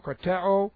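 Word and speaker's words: krateo (κρατέω): This is K-R-A-T-E, long O, krateo.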